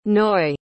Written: naois

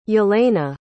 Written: yelena

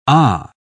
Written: ah

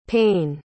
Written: payne

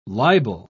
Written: libel

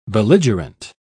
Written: belligerent

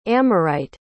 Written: amorite

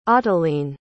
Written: ottoline

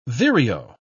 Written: vireo

